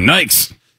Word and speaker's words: Nike: Originally, we always said Nikes. – Mm-hmm. – We didn't know it was Nike. – That's true. – Get some Nikes.